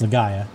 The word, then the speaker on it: Legaia: It's Legaia.